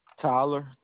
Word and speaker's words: Tyler: Tyler, T-Y-L-E-R.